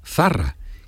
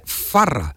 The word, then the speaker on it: Zarra: Entonces, Zarra es con Z, Zarra. Y él gritó al mundo entero el segol de Zarra y decía Farra, porque Farra.